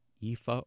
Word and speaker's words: Aoife: Her name is Aoife O'Donovan, and she is a member of a group called Crooked Stills.